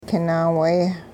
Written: kin-naawee

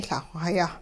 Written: Lah-xw hayah

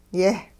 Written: yeh-

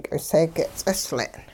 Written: k'iseege' ts'isle'n

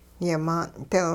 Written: yimaan'dil

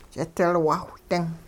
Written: ch'idilwaawh-ding

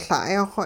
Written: La'ay-xw

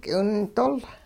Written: k'iwindol

